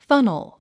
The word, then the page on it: funnel: fʌ́nl; ファノォ